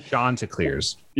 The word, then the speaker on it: Chanticleers: Andrew, you were telling me before we press record that that's the correct pronunciation. And you were Googling that earlier. Chanticleers. – Yeah, Sean with an S-H-O-N.